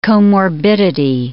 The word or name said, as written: comorbidity